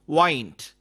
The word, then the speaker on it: wind: Here, wind means to turn or twist something. It is pronounced wind.